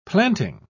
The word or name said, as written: planting